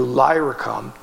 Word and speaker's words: Illyricum: From Jerusalem to Illyricum, that's how you say it, covered about 14,000 miles, which is a long way.